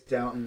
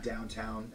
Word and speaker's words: Downton: By the way, it's Downton, not downtown.